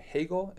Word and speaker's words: Hegel: But I saw at Tasmania University that you got you did your honors thesis on, the contributions of Rousseau and Hegel. Am I saying that right? – Correct. – Hegel. – Yes.